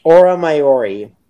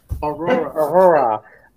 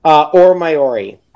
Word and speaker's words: Aura Maiori: I always butcher her name. Aura. Aura Maiori. I always say it right in casual conversation. The minute the podcast pops, I'm like, Aurora. Aura Maiori.